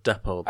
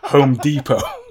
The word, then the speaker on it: Depot: Interesting that you picked two different pronunciations of depot. – I went depot when I was saying it, but then when I was doing the quote, I decided to pronounce it as a Californian might.